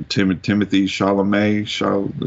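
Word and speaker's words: Timothée Chalamet: Timothy Chalamet.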